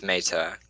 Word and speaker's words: Mata: Am I pronouncing that last that last name right? – Yeah, it gets it gets scrapped all the time. It's meta, but meta. yeah.